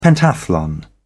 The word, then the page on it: pentathlon: British English